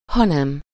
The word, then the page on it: mais: [mɛ]; /me/